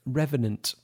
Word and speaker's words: Revenant: Revenant, that's how you pronounce it.